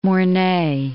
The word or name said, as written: Mornay